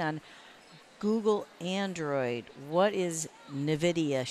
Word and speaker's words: NVIDIA: NVIDIA's Shield. Did I pronounce that right? – It's NVIDIA, but yeah, it's kind of hard to say.